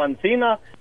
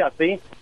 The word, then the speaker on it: así: Pues no te estoy diciendo pues que, que, que, corriges últimamente, corriges cuando alguna persona dice una palabra como ancina, tú dices, no se dice ancina, se dice así.